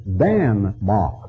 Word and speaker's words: Danmark: That's merely an anglicized pronunciation of what they call it, and that is Danmark, and that merely means the mark of Dan, where Dan dwells.